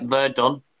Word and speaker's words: Verdun: Yeah, like Verdun. – Is it pronounced like that? – Yeah. – That's Verdun.